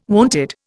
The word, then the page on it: wonted: Indian